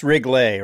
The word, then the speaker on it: Riggle: And it is pronounced Riggle, right? – Rob Riggle. No, it's Riggle. Just okay. Riggle. – All right. Thank you for clarifying that. So Rob Riggle, it's so Riggle. Good to have you. – It's not French. It's not, there's no, you're adding too much at the end. It's just Riggle. Rhymes with giggle, wiggle. It's that real simple. Just put an R in front of it. Riggle.